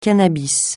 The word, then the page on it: cannabis: francais